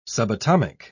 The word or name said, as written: subatomic